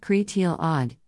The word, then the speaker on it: Creidilad: Creidilad, pronounced Cretil Odd, is the Welsh goddess of love and flowers.